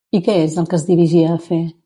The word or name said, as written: dirigia